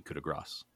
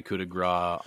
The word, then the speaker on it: coup de grâce: The final pronunciation, it depends on the alliteration that you would get when the next word that comes up has a vowel or a non-vowel. And that's would determine whether you pronounce gras or grasse.